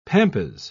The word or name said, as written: pampas